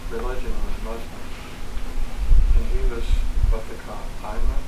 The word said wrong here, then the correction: imam: I'm not sure I'm saying that right. – An imam. – Yeah, like a priest. – Imam.